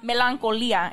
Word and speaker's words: Melancholia: The film is called Melancholia.